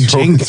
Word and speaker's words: Django: Well, like, dude, look at Django. – Throw in a 10-point. – What about Django, bro? That shit starts with a D. You don't pronounce it.